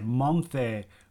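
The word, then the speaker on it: menthe: I thought it was just cream de la mint, but they spell it funky, so maybe it's just the French way of saying it. I don't know. – Yeah, I looked at the spelling. It was like monte. But when I heard it, they pronounce it more like mont. It is kind of like mint, but cream de malt.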